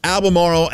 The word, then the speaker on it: Albemarle: Hey, we got a $30 gift card to use at Albemarle Anglers. Did I do that right? I know I did it right.